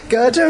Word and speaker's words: Gerda: Gerda and Kai.